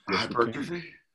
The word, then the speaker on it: hypertrophy: All right, can we talk about triggering hypotrophy and what it takes? – Hypertrophy? – Yeah. – Hypotrophy. – How do you say? Hypertrophy. – Or hypertrophy. I always have a problem with that word, hypertrophy.